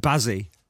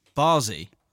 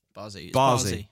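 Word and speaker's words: Bazzi: Because I'm saying Bazzy. It's not right, that. – No? – It's, well, you know, like in this country, you'd say Basil. – Barzy. – Bazy. Someone else said to Bazy. – Me. Like they say Basil in America. – Is it Barzy? Barzy.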